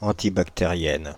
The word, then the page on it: antibactériennes: /ɑ̃.ti.bak.te.ʁjɛn/; France (Île-de-France)